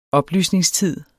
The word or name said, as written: oplysningstid